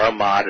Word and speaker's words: Ahmad: And what happened was I knew that General Mahmoud Ahmed, or Ahmad as they sometimes pronounce it, had been here before 9/11, just coincidentally, at least a week before.